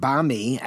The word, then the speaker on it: bami: And bami, I don't know if I'm pronouncing it correctly, but this side dish, how do you pronounce it, by the way? Is it bami or bami? – Bami.